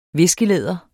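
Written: viskelæder

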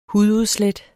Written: hududslæt

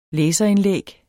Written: læserindlæg

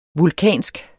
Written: vulkansk

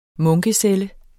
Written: munkecelle

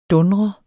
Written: dundrende

